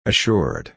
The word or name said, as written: assured